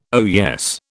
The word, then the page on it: oyez: British English